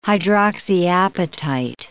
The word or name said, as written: hydroxyapatite